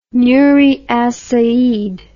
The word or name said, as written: nuri as said